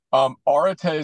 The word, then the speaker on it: Arete: Arete is how you pronounce the word.